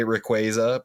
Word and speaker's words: Rayquaza: I will never say Rayquaza because that it's gross. It's Rayquaza. – Well, I don't know. But you see, here's the thing about Rayquaza's pronunciation. Because I used to be a Rayquaza guy, but then I became a Rayquaza guy. It's because you pronounce it's Quasar or Quasar. – Yeah, I know. – And that's where, like, where the naming origin kind of comes from.